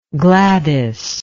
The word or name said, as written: Gladys